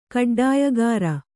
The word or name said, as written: ಕಡ್ಡಾಯಗಾರ